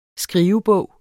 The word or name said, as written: skrivebog